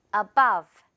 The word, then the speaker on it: above: Above. 네, 이 단어도 한 번 더 할 건데 above 아니고요. above, 버 발음입니다.